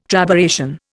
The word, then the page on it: trabeation: Indian